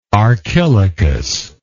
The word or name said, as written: archilochus